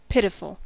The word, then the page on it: pitiful: American English